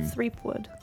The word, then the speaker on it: Threepwood: Threepwood. – Have you not Mighty heard it? Pirate. Like strep throat, Threepwood? – Threepwood? – I've heard Threepwood? It pronounced like that. – No, it's Threep. – Threepwood.